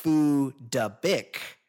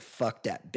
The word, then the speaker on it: Phuc Dat Bich: Apparently, the proper pronunciation, the Vietnamese pronunciation of Fuck's name is actually Phu Da Bích, which is of no use for everyone in the non-Vietnamese speaking world, because in English, it just looks like Fuck Dat Bish.